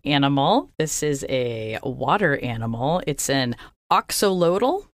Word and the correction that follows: axolotl: I think we're saying it wrong, but... – Axolotl.